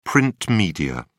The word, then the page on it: print media: British English